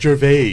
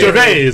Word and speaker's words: Gervais: It's pronounced Gervais. – Yeah, you got to say it. – Gervais.